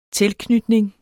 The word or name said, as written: tilknytning